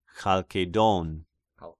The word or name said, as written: Chalkedon